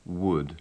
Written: wood

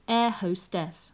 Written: air hostess

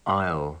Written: isle

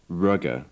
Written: rugger

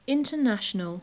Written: international

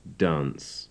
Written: dance

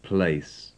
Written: place

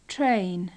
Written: train

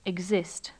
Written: exist

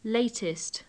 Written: latest

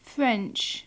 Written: French

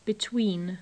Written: between

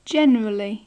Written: generally

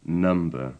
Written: number

